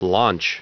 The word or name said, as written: launch